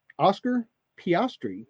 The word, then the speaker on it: Oscar Piastri: And this is our young driver of Oscar Piastri.